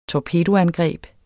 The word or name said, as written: torpedoangreb